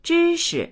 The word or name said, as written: zhi1shi